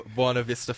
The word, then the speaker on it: Buena Vista: And it's funny you nailed the pronunciation of Buena Vista Farm.